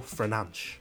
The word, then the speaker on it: Fernandes: The Portuguese Magnifico, Bruno Fernandes.